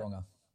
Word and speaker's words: Tauranga: How do you pronounce that? – Tauranga. – Tauranga. That's fantastic.